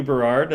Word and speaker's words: Berard: yeah, I want to say my name at sw Berard.